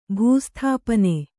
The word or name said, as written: ಭೂಸ್ಥಾಪನೆ